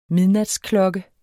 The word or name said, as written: midnat